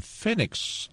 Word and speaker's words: Phoenix: Bueno, un día como hoy, del año 1997, en Phoenix, está bien pronunciado, Phoenix, Arizona. – Sí, qué lindo.